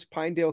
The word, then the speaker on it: Pinedale: And I hope, did I pronounce Pinedale correctly? – Because you did.